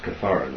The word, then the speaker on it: kithara: It's pronounced kithara.